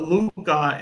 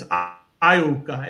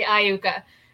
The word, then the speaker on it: Iuka: Because I call it Luca and it's Iuka, and I always say it wrong. – You know what? I think the capital I looks like an L. So we hear Luca a lot, but it is actually Iuka.